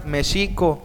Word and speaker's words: México: Y los mexicas pronunciaban Mexico, y los españoles lo escribían México, ya que no, no existía la pronunciación de la J. Cuando cambió la grafía de la letra X a la J, se la empezó a llamar México con J, pero se siguió escribiendo México con X, lo cual es válido para la Real Academia de la Lengua.